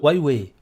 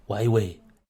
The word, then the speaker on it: Huawei: Et tu as, les géants de la Silicon Valley, tu as, euh, les Chinois, tu retrouves la, la vice-présidente d'Ouai euh, Douai, euh, alors je ne sais pas si je prononce bien, la grosse société chinoise, la multinationale, euh, qui est absolument incroyable, qui est quand même plus que soupçonnée de. Huawei, Huawei. Huawei.